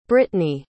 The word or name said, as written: brittny